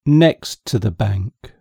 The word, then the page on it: next to the bank: British English